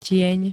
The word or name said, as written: tieň